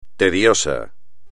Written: tedioso

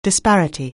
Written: disparity

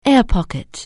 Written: air pocket

